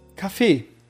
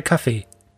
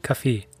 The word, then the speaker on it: Kaffee: Wie sagst du Kaffee? – Kaffee. – Kaffee, ja? Ich sag eher Kaffee. Mit einem mit schnellen E am Ende. Kaffee.